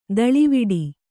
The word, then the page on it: ದಳಿವಿಡಿ: daḷiviḍi